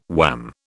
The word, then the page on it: wham: British English